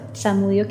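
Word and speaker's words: Zamudio: En Instagram me encuentran como arroba Carolina Samudio Castro, Samudio que es mi apellido se escribe con la letra Z, y en Facebook me encuentran como Carolina Samudio nuevamente mi apellido con Z.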